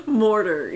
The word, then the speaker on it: mortar: I'm trying to think, you know, like, you call, sometimes you call moldering, you know, like, what, the mortal. Mortal and pestle? Is that just a different word? – Yeah, it's mortal. – Mortar, yeah. I'm sorry.